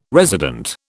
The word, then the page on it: resident: British English